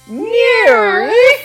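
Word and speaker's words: NIIRYF: Those are the letters for the name of the show, and it's pronounced Nears!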